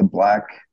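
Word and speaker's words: 6LACK: I'm listening to Black, which is spelled Six Lack.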